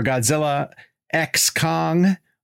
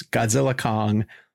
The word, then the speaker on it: Godzilla x Kong: We have Adam Wingard, who's joining us for Godzilla X Kong. Although my Warner Brothers rep told me they don't pronounce that. They're just saying it's Godzilla Kong, the new empire.